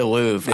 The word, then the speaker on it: Louvre: The Louvre. I was going to say it, but I didn't know how to pronounce it. – By the way, you should have went with your gut because you had it.